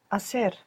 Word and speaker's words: hacer: Begins with a silent H. – Hacer.